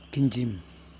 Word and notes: kindJim: Yokoim

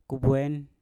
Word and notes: kubwen: Mpur

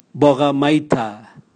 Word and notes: boga maita: Raga

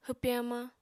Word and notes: hwpe hamañ: apatani

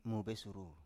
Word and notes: mobesuru: Biak

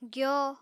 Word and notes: gyo: apatani

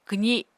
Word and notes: kwzi: Apatani

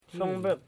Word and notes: sobip: so.bip

